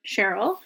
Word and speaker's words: Shirl: Yeah, so it's in the investigation that he meets Cheryl, whose name is spelt in a kind of bananas way, S-H-I-R-L, Cheryl. – But it is pronounced Cheryl.